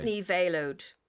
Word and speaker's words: ní Véileadh: Grainne ní Véileadh. So you pretend it's like a veil that you wear at a wedding and owed me, like your knee.